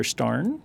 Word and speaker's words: Starn: And I just listened yesterday to Professor Starn. – Yes. – Is that how you say it? – Yeah.